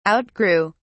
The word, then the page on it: outgrew: British English